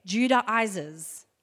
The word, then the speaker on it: Judaizers: And there was a group of radical Jewish Christians that actually were referred to as, you're going to laugh, but this is how you say it, Judaizers. Okay,